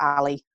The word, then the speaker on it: A11Y: If you're on something like Twitter, so it's A with 11 letters in between and then a Y at the end. So the hashtag A11Y, or Ali as I pronounce it, is used for accessibility a lot.